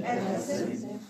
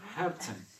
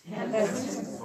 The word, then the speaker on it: Herzen: Freut mich in Herzen. – No, Herzen. – Herzen. – Und prononcez le H aspiré.